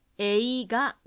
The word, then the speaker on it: 映画: 3 番は、文の間違いはないんですが、映画の発音がまだ少し英語と似ています。映画です。真ん中のイをしっかり発音しましょう。